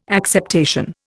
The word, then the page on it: acceptation: Indian